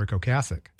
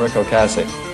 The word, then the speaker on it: Ocasek: I saw Stephen Colbert did a wonderful tribute to him, but he kept calling him Rick Ocasek. Mm-hmm. But then I go on YouTube to figure out how to say his name. And in this little video clip, he says, "Hi, I'm Rick Ocasek."